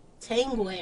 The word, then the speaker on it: Tanguay: Tangway.